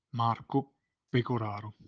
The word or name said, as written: Marco Pegoraro